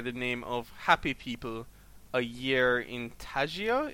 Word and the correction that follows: taiga: Is that how it's pronounced? – Taiga.